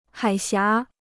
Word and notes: 海峡: hǎi xiá

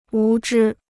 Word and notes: 无知: wú zhī

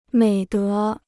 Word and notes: 美德: měi dé

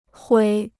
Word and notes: 毁: huǐ

